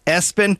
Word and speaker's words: ESPN: Also, the name ESPN, pronounced Espen.